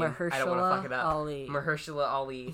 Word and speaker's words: Mahershala Ali: Did Mahershala Ali show up? – Yeah, Kevin Feige brought out... I don't know how to pronounce the name. – Mahershala Ali.